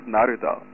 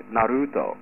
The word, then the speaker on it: Naruto: Naruto is pronounced Naruto. Like, this is my biggest pet peeve when I listen to anime podcasts in the United States. Why does everyone call Naruto, like, Naruto? Like, I can't even say it that way, Naruto.